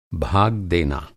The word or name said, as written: भाग